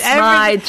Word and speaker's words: Smythe: It's Smythe. Jennifer Smythe. And for people who are looking for the book, it's spelled S-M-Y-T-H.